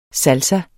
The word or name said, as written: salsa